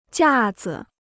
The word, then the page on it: 架子: jià zi